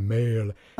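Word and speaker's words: mél: Et ça reste quand même une prononciation à l'anglaise, un MEL.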